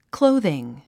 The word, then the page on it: clothing: klóuðiŋ; クロォズィン